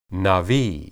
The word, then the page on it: nabi: hebrew